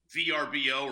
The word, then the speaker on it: Vrbo: By the way, sidebar, you can't have a company that's been a company for years and they all of a sudden want to tell you you're pronouncing it wrong, VRBO or Verbo.